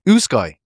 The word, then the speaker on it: Usyk: Usyk.